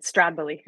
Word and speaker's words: Stradbally: That's how you pronounce it, Stradbally. – yeah, exactly. You slur the start of a Stradbally.